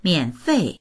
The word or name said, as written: mian3fei4